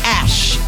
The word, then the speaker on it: Ashe: Her name is Ash. A-S-H-E. Make sure you pronounce that correctly. You might offend some people. Here's Ash.